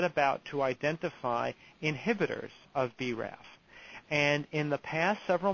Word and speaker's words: BRAF: a gene called BRAF, that's spelled B-RAF.